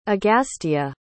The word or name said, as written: agastya